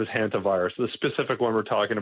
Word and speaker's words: hantavirus: I think it's called hantavirus, but I never know how scientists pronounce things. – Yeah, that's right. It's just hantavirus.